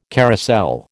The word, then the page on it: carousel: American English